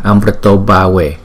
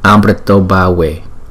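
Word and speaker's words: amṛtodbhave: Nhưng mà bởi vì chữ A với chữ U ghép lại thành chữ O cho nên chúng ta đọc là Amrito Bhave, Amrito Bhave.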